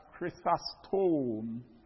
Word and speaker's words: Chrysostom: I'll spell it. Chrysostom. Chrysostom. That's right.